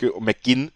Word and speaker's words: McGinn: John McGinn dürfte manchen noch ein McGinn, richtig ausgesprochen, dürfte manchen noch ein Begriff sein, spielt bei Aston Villa, also auch Premier League.